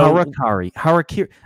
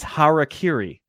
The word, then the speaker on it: harakiri: So harakiri. I absolutely pronounced it wrong. By the way, it's harakiri.